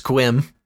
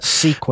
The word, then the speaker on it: Sequim: And now I am hearing from the chat that it's pronounced Squim. – Oh, Squim. – That may or may Sorry. – Not be true. I have no idea. – Sequim.